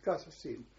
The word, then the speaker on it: Kassassin: but the Q-U is pronounced like a K, so that's Kassassin, which was a tented camp, quite big.